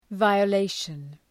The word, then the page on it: violation: ,vaıə’leıʃən